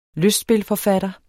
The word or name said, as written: lystspilforfatter